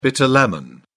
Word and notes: bitter lemon: British English

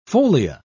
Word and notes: folia: British English

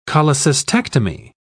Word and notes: cholecystectomy: British English

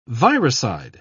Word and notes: virucide: British English

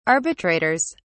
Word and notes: arbitrators: British English

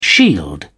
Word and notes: shield: British English

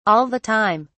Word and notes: all the time: British English

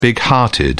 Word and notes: big hearted: British English

